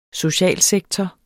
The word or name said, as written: socialsektor